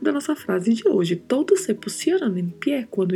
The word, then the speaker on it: todos: Em espanhol, não. É todos, todos. O sempre fechado e a letra D, a gente dá uma suavizada na pronúncia. Não vamos pronunciar tão marcado, não vamos falar todos. Não, todos.